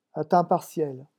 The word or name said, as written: a temps partiel